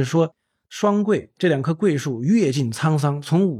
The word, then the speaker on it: 桧: 这个园子里呢有两棵巨大的桂树啊，桂是一个木字旁一个开会的会，就是秦桧的会，它是个多音字。